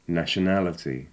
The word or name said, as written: nationality